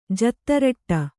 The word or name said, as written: ಜತ್ತರಟ್ಟ